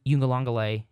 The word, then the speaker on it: Uiagalelei: And DJ Uiagalelei, that's how you say his name, I was never sold on him.